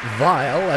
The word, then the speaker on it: Weill: 1934 piece by Kurt Weill, as he was still known then, right. Using the German pronunciation of his name before he settled here in the States.